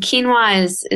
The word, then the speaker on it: quinoa: And one being quinoa, and quinoa is spelled Q-U-I-N-O-A, but it's a grain that has a lot of fiber and a lot of protein.